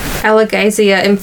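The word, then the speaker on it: Alagaësia: I am also looking at this pronunciation guide and we've got Alagazia emphasis on all caps gay in the middle. – Alagazia.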